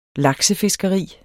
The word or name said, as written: laksefiskeri